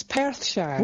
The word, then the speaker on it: Perthshire: It's Perthshire, right? – Yes, Perthshire.